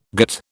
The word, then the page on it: gîte: British English